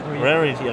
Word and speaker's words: Rarity: Wie spricht man das denn aus? – Rarity. Rarity? Rarity.